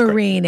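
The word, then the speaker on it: Myrin: Having listened to your excellent discussion of teasing Massachusetts humor in the episode with Arden Miram, it's Miran, mm. Mm-hmm. right? It's She's Marine, great. actually. – Is it really? Yeah. I've always said it wrong.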